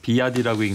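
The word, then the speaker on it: BYD: 아까 말씀드린 그 BYD라고 써 있던 비아디라고 읽는다면서요. – 네네.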